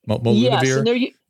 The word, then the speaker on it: molnupiravir: There's more data that's come out recently on molnupiravir. Am I saying that right? The HIV medicine that they're going to be using for, they're using early in COVID. Molnupiravir? – Yes. Yes.